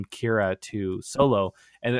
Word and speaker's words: Kira: Pronounce the Kira, Kira, Kira is how it's pronounced? – So. Yeah.